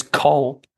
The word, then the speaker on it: Caen: It looks like it's written Cayenne, like the pepper, but I was roundly told off by my French colleagues who found out I'd done a podcast and roasted quite a lot of the pronunciation. – Really? – Yeah. – So how do you pronounce that? – So it's Valenciennes versus Col. So an A and an E should be, it's like... – A-W. – Yeah. – In Col. Okay.